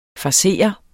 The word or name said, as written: FARSERENDE